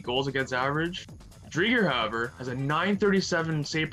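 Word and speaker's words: Driedger: And my last player is a goalie by the name of Chris, and I almost said this wrong, Chris Drieger. Not Drieger, Drieger.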